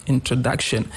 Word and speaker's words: introduction: Example, if I take the word introduction in English, the pronunciation of the word introduction, the third syllable has a stress, introduction.